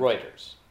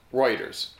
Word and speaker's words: Reuters: Reuters. – Reuters, right.